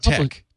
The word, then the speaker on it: TeX: It's his Tech. Tech. It's called T-E-X. – Tex. – He pronounces it Tech for just to be additionally obscure.